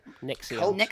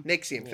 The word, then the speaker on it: NXIVM: the NXIVM, I think that's how you say it? – NXIVM. – NXIVM, thank you.